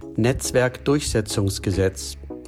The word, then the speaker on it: Netzwerkdurchsetzungsgesetz: The German pronunciation for it is Netzwerkdurchsetzungsgesetz.